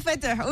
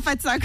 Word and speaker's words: Fatah: Comment je le prononce? – Fatah. – Je peux dire Fatah. Ok, je dis pas Fatsa comme Ahmed.